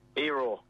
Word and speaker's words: Iro: Iro. – It's I-R-O, but it's pronounced E, Eero.